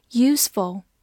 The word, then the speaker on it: useful: f u l useful，字母 u 发它本身的音，s 发 s，中间的 e 不发音，后面 f u l ful useful useful 是一个形容词，表示有用的、有益的或者有帮助的。